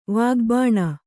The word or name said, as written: ವಾಗ್ಬಾಣ